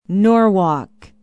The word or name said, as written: norwalk